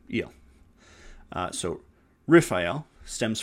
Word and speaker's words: Raphael: So, Raphael, pronounced Raphael, uh, literally translates to healing, which is of God.